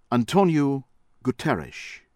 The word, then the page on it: Antonio Guterres: American English; from Portugal